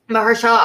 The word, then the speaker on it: Mahershala: Mahershala Ali, I know would never happen considering he's Blade and the MCU.